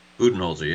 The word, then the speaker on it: Budenholzer: Is it Budenholzer? I forget how to say it. – Yeah, that sounds right. Yeah, Budenholzer.